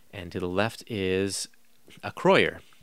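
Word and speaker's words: Acroyear: And I had to figure out how to pronounce a Groyer, because it looks like Acroyer. So I went and went to YouTube to look up some commercials of the old toys to see how Mego themselves, the toy company Mego, pronounced this character's name. And they do indeed pronounce it Acroyer.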